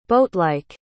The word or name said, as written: boatlike